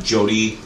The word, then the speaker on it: Jody: Jody Don Frazier, J-O-D-Y-D-O-N, Frazier, F-R-A-S-H-E-R.